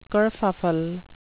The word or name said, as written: kerfuffle